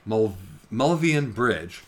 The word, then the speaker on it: Milvian Bridge: And the Millennium Bridge, sorry, I never pronounce it right, Milvian Bridge,